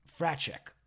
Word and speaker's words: Fraczek: How do you say it? – Fraczek. But it looks like Fresnek. – They'll fuck it all up. – Fresnek or something.